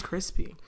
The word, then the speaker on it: Chrispy: And my name is Crispy. And that's spelled C-H-R-I-S-P-Y.